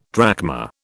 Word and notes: drachma: British English

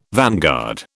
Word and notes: vanguard: British English

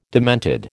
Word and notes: demented: American English

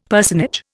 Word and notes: personage: Indian

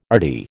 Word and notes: RD: American English

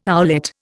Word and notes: owlet: Indian

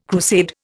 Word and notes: crusade: Indian

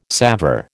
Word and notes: salver: American English

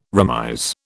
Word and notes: remise: British English

